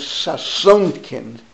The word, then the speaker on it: Sasonkin: his last name is pronounced as Sasonkin, S-A-S-O-N-K-I-N.